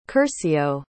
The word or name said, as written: curcio